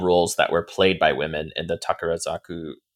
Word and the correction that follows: Takarazuka: uh, Takarazuka Revue um, shows. Um, so I think I always mispronounce that.